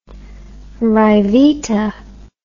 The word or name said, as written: ryvita